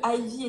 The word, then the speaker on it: Ivy: Voilà, because je suis la reine pour écorcher les Ah bah écoute, euh, déjà le Ivy était bien dit parce qu'on m'a dit que c'était Ivy à la base, donc je précisais bien que c'était Ivy.